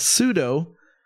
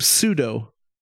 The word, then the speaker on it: pseudo: We had Steven, longtime listener of my podcast, reminded me of the correct pronunciation of pseudo because I keep saying pseudo with a P, and he found that funny because I'm German, a native speaker.